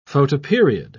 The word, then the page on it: photoperiod: British English